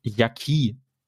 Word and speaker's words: Yaqui: Ähm, diese indigene Volksgruppe, die sich, also die, die, die Hauptindigene Volksgruppe in Mexiko ist, die wird Yaqui ausgesprochen. Das wird auch äh, kompliziert geschrieben, nämlich Y-A-Q-U-I, also Yaqui.